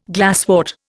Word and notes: glasswort: Indian

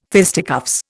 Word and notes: fisticuffs: Indian

